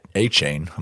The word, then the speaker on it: Achane: Okay, so Dave, you took Devin A Chain. I'm going to say that wrong about seven million times. – You just said actually, it right.